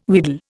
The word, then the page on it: wheedle: Indian